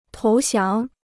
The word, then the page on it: 投降: tóu xiáng